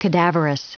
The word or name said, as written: cadaverous